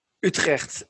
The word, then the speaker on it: Utrecht: Wait, is that really how you pronounce that word? – Utrecht is the...